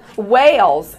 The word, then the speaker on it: Wales: By the way, my name is Tamara Wales. Tamara, it rhymes with camera, okay? Wales, like the country and not the mammal.